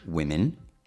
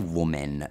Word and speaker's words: women: Perché è scritto W-O-M-E-N, ma in realtà donne si pronuncierebbe, aspetta, U-I-M-I-N. Women, women, ok?